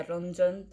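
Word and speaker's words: Renjun: Después tenemos a Johnny, Tayon, Yuta, Yuta, Jesus Kun, Doyeon, Ten, Jaehyun, Winwin, Juno, Lucas, Mark, Xiaoyeon, Hendery, Ranjan. Eh, el nombre en realidad se pronuncia Ronjon porque es chino.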